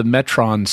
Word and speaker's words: Metron: and he keeps everyone pronounces it Metrone, even though the Metrons pronounce it Metron. – Yeah. – And in Greek, it would be pronounced Metron, because that's an Omicron, not an Omega.